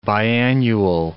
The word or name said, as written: biannual